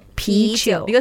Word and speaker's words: 啤酒: 啤酒. – Because they just take the pronunciation from beer.